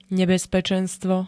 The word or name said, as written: nebezpečenstvo